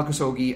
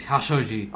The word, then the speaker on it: Khashoggi: So really quickly, the issue is Jamal Khashoggi. – Is Khashoggi. – Oh, okay, thank you.